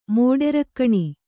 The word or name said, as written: ಮೋಡೆರಕ್ಕಣಿ